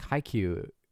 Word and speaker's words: Haikyuu: Because like Haikyuu, I don't know if I'm pronouncing it correctly, but...